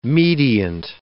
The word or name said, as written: mediant